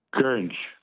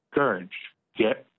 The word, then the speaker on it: goringe: The word that rhymes with orange is Gurnj. – Is what? Gurnj? – Gurnj, yep. – What's the spelling of Gurnj? – G-O-R-I-N-G-E. – And what does that mean? – So it's a common word used in cosplay.